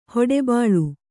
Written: ಹೊಡಬಾಳು

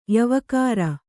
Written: ಯವಕಾರ

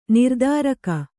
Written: ನಿರ್ಧಾರಕ

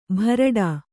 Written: ಭರಡಾ

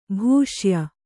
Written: ಭೂಷ್ಯ

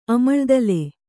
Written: ಅಮಳ್ದಲೆ

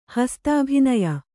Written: ಹಸ್ತಾಭಿನಯ

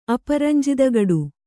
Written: ಅಪರಂಜಿದಗಡು

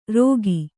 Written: ರೋಗಿ

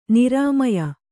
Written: ನೀರ್ಮೆಯ್

